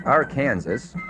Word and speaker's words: Arkansas: Arkansas is the state most frequently mispronounced. Pronounced Arkansas,